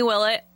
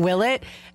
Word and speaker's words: Willett: Which, I went to school with a girl with her last name Willett. So, like, I've had to work really hard to say his name Willett, because all weekend I was saying Willett.